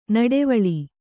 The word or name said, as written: ನಡುವಳಿ